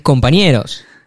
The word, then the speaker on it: compañeros: No, es compañeros. El acento está en la E, no está en co.